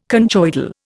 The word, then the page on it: conchoidal: Indian